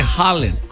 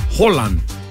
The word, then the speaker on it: Haaland: Todavía no lo estoy diciendo bien, pero se dice, en vez de decir la A, la doble A se convierte como en O. – Oh, wow. – Holland.